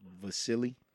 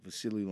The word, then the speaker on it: Vasily: Wait, say that name again. – Vasily. – You saying it right? – Yeah. – Okay. – Yeah, Vasily Lomachenko.